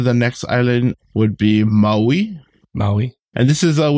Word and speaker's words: Maui: So kind of more biting down on the on the vowels. Maui.